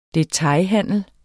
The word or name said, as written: DETAILHANDEL